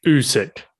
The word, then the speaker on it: Usyk: It was Oleksandr Usyk, I hope I pronounced that correctly, against Anthony Joshua. Is it Usyk? – Usyk.